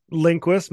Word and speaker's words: Lindquist: I think like the most prominent rider's name is Max Lundquist. Did I say that right? – Lindquist, Max Lindquist, Wesley Wolf's planning to do it, Michael Allred maybe.